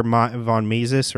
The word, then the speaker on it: von Mises: He studied under von Mises, or Mises, I don't know how you say it.